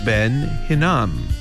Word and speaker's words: Hinnom: 넵도아, 넵도아를 넵도아 이렇게 발음해 주시고, 흰놈의 아들 골짜기, 골짜기는 벨리고 흰놈은 히남 이렇게 발음합니다. 그래서 잘 듣고 따라해 보시면 The Valley of Ben Hinnom.